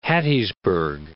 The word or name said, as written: Hattiesburg